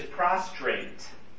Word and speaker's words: prostrate: And the word in Greek is prostrate. Prostrate. Well, that's interesting. I gotta make sure I say it right. I miss a letter in there, it's, you know.